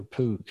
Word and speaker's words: Pogue: Pogue or Pogue? How do you pronounce it? – Pogue.